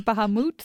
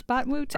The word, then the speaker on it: Bahamut: There are many different ways to pronounce Bahamut. There's Bahamut, there's Batmut.